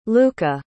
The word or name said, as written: lucca